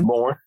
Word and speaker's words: Bourne: Is that how you say it? Born? Pierre Bourne. – I think it's just Bourne.